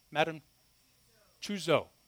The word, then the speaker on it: Tussaud: I'm probably not saying that right, all of you French people in here. Have any people that speak French? Go ahead, don't be embarrassed. No, how do you say that? Madame Tussaud. There you go.